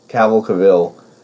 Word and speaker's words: Cavill: Cavill fighting on a fucking mountain.